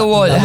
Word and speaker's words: Wolfgang Reitherman: Que no se dice Wolf. – La doble U suena como B corta. – Bol. Sí, okay. Por eso es Wolf.